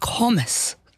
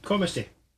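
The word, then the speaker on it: Comice: Which fruit has varieties including confidence and commis? Am I saying that right? – Commissy.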